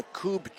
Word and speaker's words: Kubb: For me, people want more information on the Coupe Championships, go to USA Coupe, that is spelled K-U-B-B.